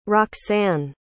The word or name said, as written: roxanne